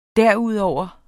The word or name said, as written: derudover